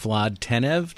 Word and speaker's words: Vlad Tenev: And why are Baiju Bhatt and Vlad Tenev, did I say that right? – You did.